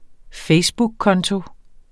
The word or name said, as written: facebookkonto